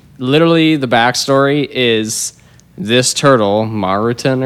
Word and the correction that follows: Maturin: or something. – Maturin? That's how it's spelled. – It happens in the book. – Oh, yeah. – Maturin? – It's spelled Maturin. – Yeah, Maturin. You're right.